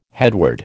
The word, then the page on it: headword: American English